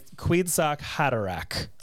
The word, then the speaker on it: Kwisatz Haderach: She accuses Jessica of thinking that her son might be Kwisatz Haderach, the fulfillment of I'm going to keep butchering these names, so you tell me if I'm wrong, okay? – Kwisatz Haderach.